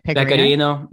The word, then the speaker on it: Pecorino: Pecorino. Is that how you say it? – Pecorino. Yeah, oh, it's the best.